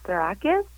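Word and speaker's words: Psarakis: Am I pronouncing your last name right, first of all? – Actually, it's Sarakis. – Sarakis. – Close enough. – Sorry, didn't have the right emphasis.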